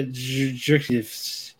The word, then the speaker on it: Jircniv: Yeah, Jerkniv.